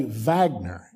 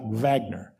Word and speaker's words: Wagner: And then there is this other group called the if you hear it on the news, they're going to say Wagner, V-A-G-N, like Wagner. We spell it Wagner. So if you read it, it'll be W-A-G-N-E-R. An English term is Wagner, but if you hear it on the news, they'll say Wagner.